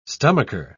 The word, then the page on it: stomacher: British English